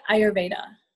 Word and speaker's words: Ayurveda: Oh, you can say like Ayurveda.